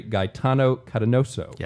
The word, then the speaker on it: Gaetano Catanoso: Now, I'm going to try to pronounce this correctly. Padre Gaetano Catanoso. – Yes, that's right. – Okay, that's correct.